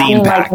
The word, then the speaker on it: ZinePack: It's ZinePack, like magazine. Yeah, so you were super close.